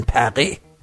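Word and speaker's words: Paris: من بار اول که فرانسه خوندم و متوجه شدم که به پاریس میگن پقی، خیلی ناراحت شدم که حتی اسم پایتخت کشور خودشونم نمیتونن درست تلفظ کنن.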